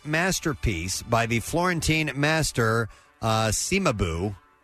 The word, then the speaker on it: Cimabue: It's Cimabue.